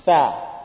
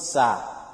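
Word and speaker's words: tha (ثاء): Tha, the tongue will come out. If you pronounce this letter and the tongue is not out, you must know it's wrong. And it's not sa. Some they will, instead of tha, they will say sa.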